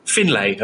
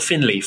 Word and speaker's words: Finlay: Finlay, apparently it's pronounced, not Finley, Finlay. He says it Finlay.